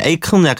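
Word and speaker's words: Akon: 에이, 에이콘? 아, 에이콘? 근데 약간 발음을 에이큰 약간 이런 식으로 하더라고요.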